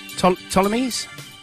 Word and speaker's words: Ptolemies: How do you pronounce P-T-O-L-E-M-I-E-S? Ptolemies? Ptolemies. – Probably a silent P. – So the Ptolemies, or is it Ptolemies? – I reckon it's a silent P, yeah.